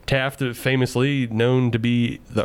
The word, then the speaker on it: Taft: Taft rhymes with draft.